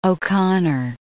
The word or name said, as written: O'Connor